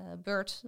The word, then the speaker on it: BERT: Bert, op zijn Engels uitgesproken.